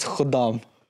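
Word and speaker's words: Khuddam: So, Shwakbay, I'm going to correct you one more time. It's Kadam. – Khudam.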